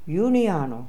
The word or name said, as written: Uneano